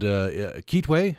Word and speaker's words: Kitwe: You were in a town called Kitwe. Am I pronouncing that correctly? – Yes.